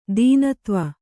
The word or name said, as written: ತೆನೆತೀವು